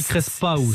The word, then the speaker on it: crespeou: Alors, ça s'écrit crespeou, mais ça se dit crespeou. – C'est ça, si on le prononce avec l'accent provençal.